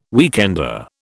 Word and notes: weekender: British English

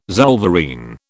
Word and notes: zollverein: British English

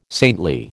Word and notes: saintly: American English

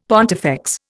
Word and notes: pontifex: Indian

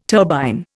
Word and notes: turbine: Indian

